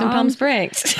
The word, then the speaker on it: Palm Springs: What's your favorite place in Palm Springs? – Palm Springs. First of all, I like to pronounce the L.